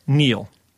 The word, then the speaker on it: kneel: K-N-E-E-L. Neil.